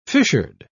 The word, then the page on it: fissured: British English